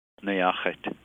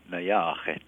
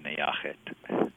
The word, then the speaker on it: Neyooxet: Yeah, the Arapaho is a tonal language, and so there's a rising and falling in the where the O's are. So, NEYOOXET. But most people just say NEYOOXET.